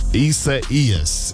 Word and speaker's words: Isaias: Isaias is how you say it. Isaias.